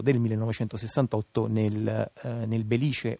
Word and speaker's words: Belice: tutti dicevano Velice perché venendo dal nord eh, ovviamente eh, mm, poco conoscendo il siciliano non, eh, non potevano apprezzare il belice come si dice invece in siciliano.